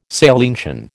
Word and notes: salientian: American English

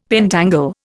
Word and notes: pentangle: Indian

